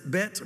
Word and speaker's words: Bet: Again, they pronounce it Bet rather than the H, not the Beth sound that we say, but Beth, however you want to say it.